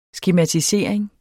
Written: skematisering

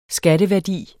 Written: skatteværdi

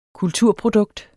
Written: kulturprodukt

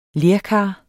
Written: lerkar

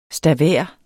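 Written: STAVÆRERNES

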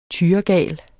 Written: tyregal

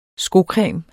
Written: skocreme

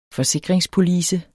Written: FORSIKRINGSPOLICE

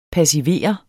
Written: passivere

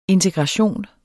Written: integration

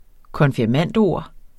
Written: konfirmandord

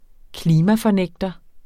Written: klimafornægter

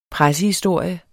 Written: pressehistorie

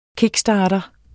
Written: kickstarter

